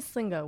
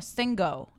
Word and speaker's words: Stingo: Stingo.